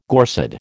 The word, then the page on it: Gorsedd: American English